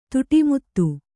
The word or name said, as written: ತುಟಿಮುತ್ತು